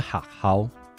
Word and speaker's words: Hahow: 首先是 Hahow，它其实就是台语中“ 学校” 这个词的发音。